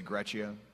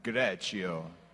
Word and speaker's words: Greccio: Everyone say Greccio. And now say it like an outrageous Italian. Greccio.